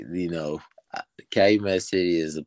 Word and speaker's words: Calumet City: But going back to kind of where the beginning of where everything started, you grew up in Calumet City, Illinois. Is that correct? – Calumet City.